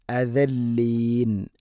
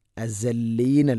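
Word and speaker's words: അദല്ലീൻ (الأذلين): അദല്ലീൻ, അസല്ലീൻ അല്ല, ദാലാണ്.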